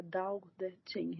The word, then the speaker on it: Tao Te Ching: Jag stötte på en liten översättning av Tao Te Ching, eller Tao Te Ching heter det väl om man skulle uttala det rätt.